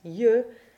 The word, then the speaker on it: yeux: Sometimes, like in the last word of this text, yeux, it's simply a J glide,